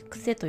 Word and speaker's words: 努力: 自分を苦しめる努力の手放し方、努力と書いて癖と読むんですけれども、自分を苦しめる努力の手放し方で全国書店もしくはアマゾンで、見ていただけると、販売しておりますので、よかったらお手に取ってみてください。